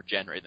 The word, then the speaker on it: Jenry: Yeah, I think it was the pronunciation had an X in it, which is kind of more like Jenry, which is kind of more Jenry than Henry.